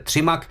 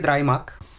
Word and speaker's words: DryMag: Povídáme si o projektu DryMag, nebo chcete-li Třimak. My už jsme to tady o tom mluvili. Ta správná výslovnost je vždycky DryMag.